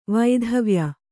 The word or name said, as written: ವೈಧವ್ಯ